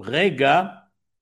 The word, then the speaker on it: rega: La deuxième réponse de Tosfot, c'est de dire que le mot rega, il a 2 syllabes.